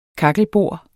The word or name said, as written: kakkelbord